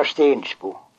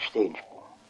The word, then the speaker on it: Steningsbo: Det säger uttalar stensbo. – Var det det gamla uttalet det? – Ja, det är det gamla uttalet, stensbo.